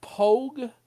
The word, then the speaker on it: POG: First POG is pronounced Pogue in military parlance.